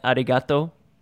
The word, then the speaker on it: Arigato: And am I saying it right? Arigato. – Yes, you sound like uh, uh, my brother.